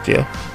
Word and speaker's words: Xia: Um, XIA, which is pronounced Jia, um, I think, I'm pretty sure.